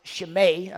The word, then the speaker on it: Shimei: Shimei, as his name is often pronounced, I pronounce it Shimei, I think it's closer to the Hebrew.